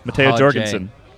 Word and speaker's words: Matteo Jorgensen: Corrections corner. I was soft J-ing. – It's a hard J. – Matteo Jorgensen.